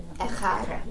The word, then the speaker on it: echad: How do you say it? E-C-H-A-D. – Echad.